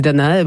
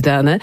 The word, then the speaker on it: Danae: Czy o, w zależności jak to lubi wymawiać, Danae bądź Danę, starożytną księżniczkę Argos, będącą córką króla Akryziosa i, uwaga, jedną z kochanek Zeusa, czy może o inny obraz?